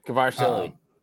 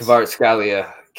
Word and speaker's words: Kvaratskhelia: Kvaratskhelia. – Yes. – Kvaratskhelia.